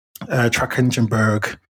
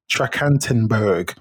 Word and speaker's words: Trachtenberg: So that director is Dan Trachtenberg. I just totally pronounced that wrong, but it's something. It's Dan Trachtenberg.